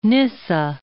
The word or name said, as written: Gregory of Nyssa